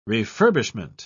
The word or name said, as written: refurbishment